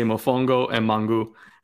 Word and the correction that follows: Mofongo: The N is silent. It's like Mofongo.